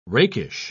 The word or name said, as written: rakish